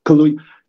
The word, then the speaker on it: Kaluuya: Daniel Kaluuya, I always mispronounce it. Kaluuya. Loved seeing him, his mama, his sister.